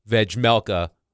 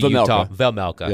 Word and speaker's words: Vejmelka: Saros's 53 starts are second most in the NHL behind Vejmelka of the Vejmelka. Yeah. Sorry, is the J silent? A silent J?